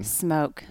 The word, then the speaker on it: Smoak: Smoak.